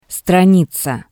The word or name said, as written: straniza